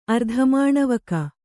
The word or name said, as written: ಅರ್ಧಮಾಣವಕ